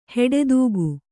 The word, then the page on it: ಹೆಡೆದೂಗು: heḍedūgu